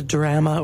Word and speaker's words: drama: It's the drama or the drama. So some people say drama to rhyme with Alabama, but these guys didn't sell out to that.